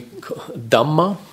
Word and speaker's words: Dhamma: The name for it is confusingly Dhamma, with a long A at the end, meaning phenomena.